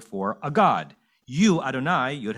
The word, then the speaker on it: Adonai: Whereas the word we pronounce as Adonai, but is spelled Yud-Heh-Vav-Heh, or Yud-Keh-Vav-Keh, depending on how you say it, seems to be the specific name or experience that we believe that our ancestors and we had with God.